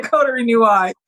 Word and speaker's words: Coterie noir: Did I pronounce mm-hmm. it coterie? Coterie? – Yes. The coterie noir.